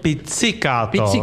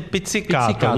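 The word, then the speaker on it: pizzicato: Ale je to picikáto, Vladimíre. To je správná výslovnost, je picikáto. Nikoliv to, co ty tak. Považuješ za sprostý, to je špatná výslovnost.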